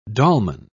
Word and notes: dolman: British English

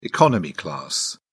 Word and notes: economy class: British English

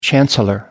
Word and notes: chancellor: American English

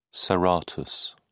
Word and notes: serratus: British English

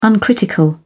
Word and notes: uncritical: British English

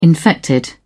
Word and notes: infected: British English

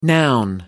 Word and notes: noun: American English

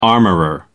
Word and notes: armourer: American English

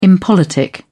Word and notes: impolitic: British English

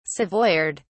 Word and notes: savoyard: British English

